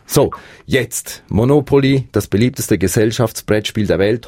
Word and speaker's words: Monopoly: Also die korrekte Aussprache ist Monopoly mit der Betonung auf dem zweiten O.